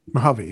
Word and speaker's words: Mojave: Mojave, yeah.